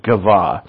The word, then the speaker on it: Gibeah: I've been calling this Gibeah. I finally looked it up today, and it seems like a better pronunciation is Gibeah.